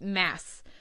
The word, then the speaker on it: Mads: It looks like Mads or Mads. It's pronounced Mass.